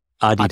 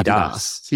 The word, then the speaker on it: Adidas: Um, I believe it's have, pronounced Adidas. Adidas. Yeah, Adidas, Adidas.